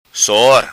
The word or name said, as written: Sor